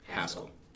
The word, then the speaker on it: Haskell: And I apologize if I am butchering that name. I don't have that drawl that you guys have up there. – It's Haskell. – Haskell? – Haskell. – It is confirmed.